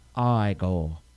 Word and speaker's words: Igor: Marty Feldman as Igor. – Yes. You mean Igor? – It's pronounced Igor.